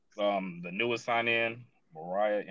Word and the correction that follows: Moira: M-O-I-R-A. – Moira, okay.